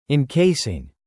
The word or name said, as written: encasing